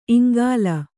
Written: ಇಂಗುಲೆ

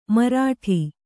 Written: ಮಾರಾಟ

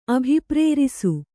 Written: ಅಭಿಪ್ರೇರಿಸು

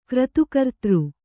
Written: ಕ್ರತುಕರ್ತೃ

